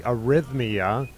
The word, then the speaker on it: Arrhythmia: Yeah, and part of that will be the Arrhythmia, right? Did I pronounce that right? – You did, yes.